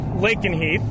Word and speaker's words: Lakenheath: Um, so you mentioned uh, Lakenheath.